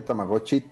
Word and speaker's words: Tamagotchi: Sí, hay, hay, hay cosas muy interesantes y acabo de descubrir que Tamagotchi lleva T intermedia.